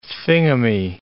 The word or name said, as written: thingummy